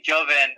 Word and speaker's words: Jovan: Yeah, I know on it has an A, but I was always called with an E, Jovan.